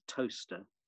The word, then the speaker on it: Towcester: And then when we landed in a place called Towcester, which is spelled T O W C E S T E R, uh, nothing to do with bread at all.